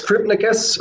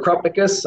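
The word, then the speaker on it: Krupnikas: It's Krupnikus or Krupnikus, I think. Either way is probably okay.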